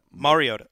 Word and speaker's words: Mariota: Mariota.